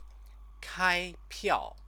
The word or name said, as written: kaipiao